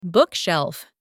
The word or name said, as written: bookshelf